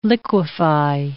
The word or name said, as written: liquify